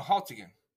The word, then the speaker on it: Haltigan: Is the G silent? – No, Haltigan.